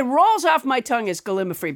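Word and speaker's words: gallimaufry: I think you might have mispronounced gallimafree there again. – Oh, I did. You're right. Gallimafree. My mistake.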